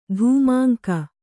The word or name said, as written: ಧೂಮಾಂಕ